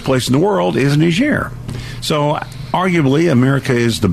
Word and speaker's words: Niger: In fact, where I was most recently in Niger, it's a French colony, or was, and so they pronounce it Niger.